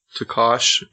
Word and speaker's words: tokosh: So the Peruvians, they have a certain potato, tokosh, if I'm pronouncing tokosh. – Tokosh. Tokosh. – Tokosh. Yes. Okay.